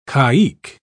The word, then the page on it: caique: British English